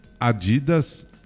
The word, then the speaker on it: Adidas: É uma marca alemã, a pronúncia dela na Alemanha é Adidas, Adidas ou Adidas? – O acento é no A, Adidas.